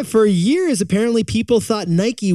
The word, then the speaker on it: Nike: One of the most historically mispronounced brands of all time. – What is it? – It's Nike. – It's Nike. – I mean Nike. – I like what you did there. Now, we've always said Nike, like two syllables. I feel like we grew up saying Nike.